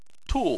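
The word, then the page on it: tu: tone 4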